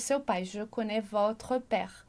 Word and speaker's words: votre: E aqui tem um detalhe de pronúncia importante. A gente diz lá pro adjectif possessif, a gente vai dizer votre. E aqui vem o accent circonflexe para fazer com que a gente pronuncie votre. Então, olha lá a diferença. Votre, sem o acento, votre.